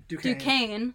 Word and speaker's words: Duquesne: They're one of 2 teams to do that in the last 20 years, and the other team, Duquesne, am I saying that right? – Duquesne.